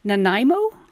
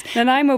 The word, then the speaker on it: Nanaimo: Listen, you have a range of delicious treats, lemon truffles, Canadian, is it Nanaimo? – You said it right. Nanaimo bars.